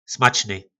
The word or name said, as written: smacny